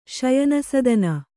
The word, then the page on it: ಶಯನಸದನ: Kannada